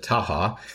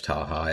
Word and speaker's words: Tacha: After law school, you clerked for Judge Dinel Tacha on the 10th Circuit. What was that experience like? – Yeah, forgive me for correcting the pronunciation, but she pronounces it Taha.